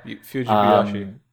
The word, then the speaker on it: Fujibayashi: Hiroshi Fujibayashi. I think it's that, yeah, Fujibayashi.